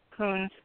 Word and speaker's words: Kunz: Lewis Kelch, Arnold Voss, Morris Coons, to me, the way that name is pronounced and spelled K-U-N-Z, it would seem that it would have an E in it also if it was Coons. That's why I use a short U sound, sorry.